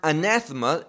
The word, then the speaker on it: anathema: Now, that word, the word anathema, is really a Greek word. That's how you pronounce it in Greek.